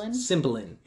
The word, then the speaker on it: Cymbeline: Cymbeline, yeah.